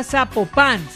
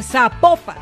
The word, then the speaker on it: Zapopan: Oiga, nos escriben, nos escriben los oyentes y que no se, no se pronuncia Zapopan, sino que se pronuncia Zapopan. – Ah, Zapopan.